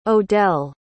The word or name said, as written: odell